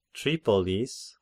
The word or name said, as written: Tripolis